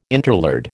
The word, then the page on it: interlard: American English